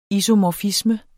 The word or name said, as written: isomorfisme